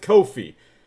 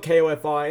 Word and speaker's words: Ko-fi: Ko-fi. It might be coffee, but it's spelled K-O-F-I, and that's weird, so I say it Ko-fi.